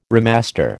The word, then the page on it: remaster: American English